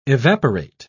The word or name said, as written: evaporate